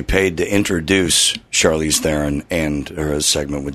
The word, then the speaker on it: Charlize Theron: It's Charlize Theron. Somebody give him the message so he can rant on me, because drives me nuts. I must have called in 10 times about this. Not Theron, it's Theron.